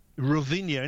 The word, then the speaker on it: Rovinj: and it's a beautiful little fishing town called Rovinja. – It's Rovinja, and it's actually spelt Rovinj, but in Croat you don't pronounce the J, don't you know?